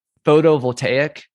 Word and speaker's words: photovoltaic: There is the nuclear, which we'll discuss later, of the fission and fusion, the photovoltaic, which, correct me if I'm saying that wrong. Did I say it right? – Oh, that's right.